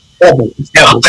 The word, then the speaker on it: Evils: Oh, did I say that wrong? Damn, I said the Evals. – I got to figure out a way how to make people, because it is like an evaluation, eval. Right. But it's Evils.